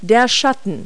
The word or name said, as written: shadow